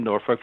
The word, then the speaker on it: Norfolk: And he says, because you have to pronounce it properly. That's how they say it in Norfolk, Virginia. I says, okay, two things here. Myself and my character are both from California. – There you go. – We say it like this.